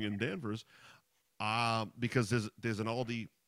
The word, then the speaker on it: Aldi: So, it's Aldi. – Aldi? – Aldi. Thank you.